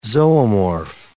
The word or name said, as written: zoomorph